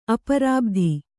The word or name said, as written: ಅಪರಾಬ್ಧಿ